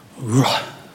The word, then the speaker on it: ruach: But wait, the way it's actually said in the Hebrew, it starts with that ru sound, but then it ends in like a breath. Ruah.